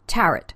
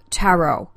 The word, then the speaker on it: tarot: I studied under a wonderful teacher in uh, California who had been born in Italy, and she also said tarot. Now, what's happened is Americans who are notoriously insecure about pronunciation, especially of foreign words, have really taken on the French version of pronunciation, which is sort of tarot or or tarot, I guess.